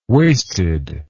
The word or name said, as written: wasted